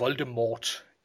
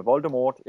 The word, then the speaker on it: Voldemort: Og derfor bliver det Voldemort i, i filmene, men tid skal altså ikke udtales.